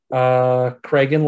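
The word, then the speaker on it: Cregan: Craigan, like Megan? – Yeah, Craigan.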